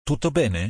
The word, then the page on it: Tutto bene: Italian